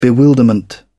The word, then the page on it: bewilderment: British English